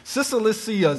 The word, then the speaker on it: Cilicia: It says this: "I am a Jew, born in Tarsus of," "Cilicia, blah, blah, blah."